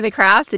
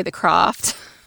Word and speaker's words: The Craft: Because we say The Craft and you say The Croft.